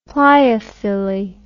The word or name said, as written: pleiophylly